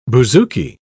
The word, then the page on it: bouzouki: British English